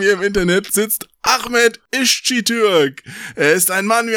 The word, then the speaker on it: Iscitürk: Wie spricht man das aus? – Ischitürk. – Ischitürk.